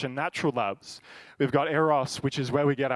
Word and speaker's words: Eros: Eros, got to roll the R.